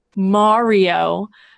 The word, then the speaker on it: Mario: See, look, I'm saying it properly. Mario.